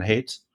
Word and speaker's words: Haidt: Jonathan Haidt? – I think so.